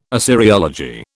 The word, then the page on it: Assyriology: British English